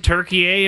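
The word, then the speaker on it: Türkiye: Three syllables, Turkey-ye.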